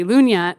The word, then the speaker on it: Lunya: The domain, So there's a word in Swedish that's that is basically Lunya, but it's L-U-G-N-A. – Yeah. – Americans would call that Lugna. – Yeah. – But I spelled it phonetically, and it means calm.